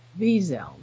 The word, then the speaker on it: Wiesel: And they appear to be in the moral, like here, it's hard to say that because the accent is not on the second syllable. And it's hard to use the Z without it. It really would be Wiesel. That's how you say it.